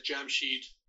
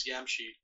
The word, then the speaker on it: Jamshid: There's a Norse equivalent, apparently, too, to Jamshid, at least according to some scholars, like a gentleman named Ottinger, who claims that Jamshid, which again can also be pronounced as Yamshid, is the equivalent of the Norse god Ymir.